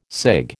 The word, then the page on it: cig: American English